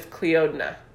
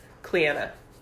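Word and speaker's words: Clíodhna: And there was this girl in my high school, her name was spelled C-L-I-O-D-H-N-A. It was Cleodna. – Cleodna. – But her name was, like, pronounced Cleanna.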